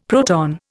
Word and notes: proton: Indian

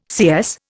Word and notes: CS: Indian

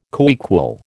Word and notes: coequal: American English

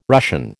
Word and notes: Russian: American English

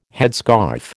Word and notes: headscarf: American English

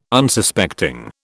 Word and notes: Unsuspecting: British English

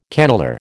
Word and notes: cannelure: American English

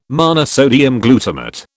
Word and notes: monosodium glutamate: British English

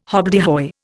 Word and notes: hobbledehoy: Indian

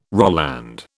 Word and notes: Roland: British English

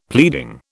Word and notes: pleading: British English

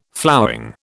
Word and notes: flowering: British English

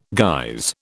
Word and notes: guise: British English

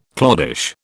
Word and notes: cloddish: British English

